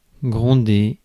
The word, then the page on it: exhort: [ɨɡˈzʰɔɹt]